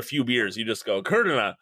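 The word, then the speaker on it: Kudrna: So Ben Kuderna, the high-profile starting pitcher prospect. Kuderna, yep. – Wow, assumed it was Kuderna, you know, like Favre. The R comes after the V, but it's pronounced.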